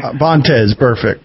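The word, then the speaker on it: Vontaze Burfict: So I was watching your show recently, and you were talking about Burkez Burfitt, I think. Did I pronounce his name? – Vontaze Burfitt. – Vontaze, yeah.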